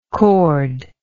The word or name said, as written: chorded